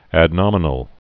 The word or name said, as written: adnominal